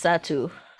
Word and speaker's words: Satou: So Hey man, hey man I, I, ain't been as tapped in, but is Satou Sat am I pronouncing that correctly? – Yes, Satou.